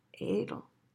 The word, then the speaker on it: Adl: And the practice is, it's spelled A-D-L, A-D-L, and it's pronounced Adl, Adl.